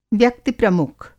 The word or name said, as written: व्यक्ति-प्रमुख